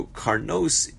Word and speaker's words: karnos: So karnos is pronounced. There's no vav written. It's pronounced if there was vav.